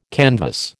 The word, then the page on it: canvas: American English